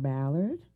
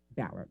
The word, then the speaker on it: Ballard: My name is Sarah Ballard. – Sarah? – S-A-R-A, no H. – That's right. – And Ballard? – Ballard, yes. – Spell that for me. – B-A-L-L-A-R-D, as in Delta.